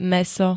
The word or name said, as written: mäso